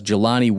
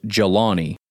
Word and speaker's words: Jelani: At pick 132, he has Jelani Woods, tight end. This is number zero, as my son would refer to him. 6'7", 253 pounds, 23.55 years old. And apparently it's pronounced Jelani, not Jelani. Jelani.